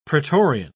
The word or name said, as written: praetorian